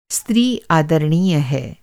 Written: आदरणीय

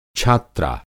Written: छात्रा

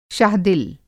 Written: शाह दिल

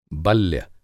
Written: बल्य

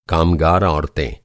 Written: कामगार

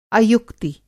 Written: अयुक्ति

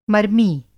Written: मर्मी

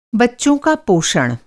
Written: पोषण

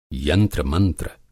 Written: यंत्र-मंत्र

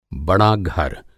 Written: बड़ा घर

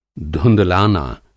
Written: धुँधलाना

